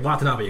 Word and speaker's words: Watanabe: Shinichiro Watanabe. I don't know if I'm pronouncing that quite right. – Watanabe, you got pretty straight, yeah.